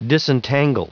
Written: disentangle

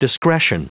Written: discretion

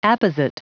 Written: apposite

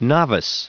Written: novice

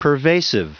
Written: pervasive

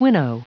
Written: winnow

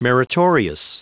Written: meritorious

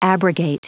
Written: abrogate

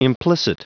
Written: implicit

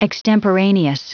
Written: extemporaneous

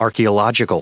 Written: archaeological

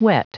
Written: whet

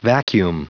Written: vacuum

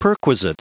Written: perquisite

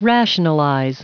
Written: rationalize

